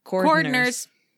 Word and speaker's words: cordwainers: In 1465, they were banned in England altogether so that all cordoners. It's spelled cordwainers.